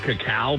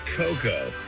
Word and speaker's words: cacao: So is that cacao beans? Because I don't think it's cocoa. – Yeah, I've always heard cacao. – Cacao. It's spelled different, though. It's spelled C-A-C-A-O.